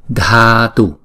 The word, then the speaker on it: Dhatu: Chúng ta chú ý rằng chữ Dhatu là chữ D và chữ H ghép lại, chúng ta đọc là Dha, Dha, Dhatu, Dhatu, Om Vajradhatu Vam.